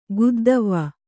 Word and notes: ಗುದ್ದವ್ವ: guddavva

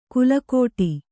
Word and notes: ಕುಲಕೋಟಿ: kulakōṭi

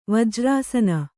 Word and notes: ವಜ್ರಾಸನ: vajrāsana

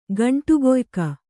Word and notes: ಗಂಟೆಕಾಯಕ: gaṇṭe kāyaka

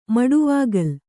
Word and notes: ಮಡುವಾಗಲ್: maḍuvāgal

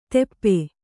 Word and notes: ತೆಪ್ಪೆ: teppe

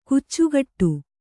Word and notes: ಕಚ್ಚೆಗಟ್ಟು: kannada

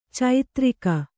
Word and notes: ಚೈತ್ರಿಕ: caitrika

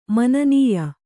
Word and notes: ಮಾನನೀಯ: mānanīya

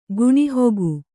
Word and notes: ಗುಣಿಹೊಗು: Kannada

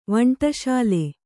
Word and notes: ವಂಟಶಾಲೆ: kannada

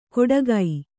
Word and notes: ಕೊಡಗೈ: kannada

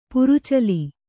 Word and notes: ಪ್ರಚಲ: pracala

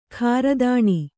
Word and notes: ಖಾರದಾಣಿ: khāradāṇi

